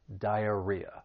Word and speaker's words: diarrhea: For pronunciation, the word has four syllables. The stress is on that third syllable, diarrhea.